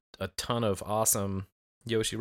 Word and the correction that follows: Joshi: wrestlers. Is it Joshi? Joshi. Am I, res- I okay, yeah. Yes, it's Joshi. Joshi.